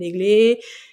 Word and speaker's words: Eglé: Moi, je leur disais évidemment mon prénom, donc je m'appelle Aiglé. Et j'essayais de, leur faire prononcer Aiglé, donc euh, évidemment, ce euh, c'était pas toujours réussi.